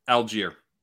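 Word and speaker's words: Allgeier: How do you pronounce his last name? – Algier. – Tyler Algier. All right. Just like it looks.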